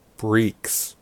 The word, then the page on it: breeks: American English